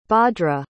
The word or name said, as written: Bhadra